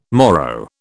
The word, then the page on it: Moro: British English